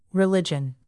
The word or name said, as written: religion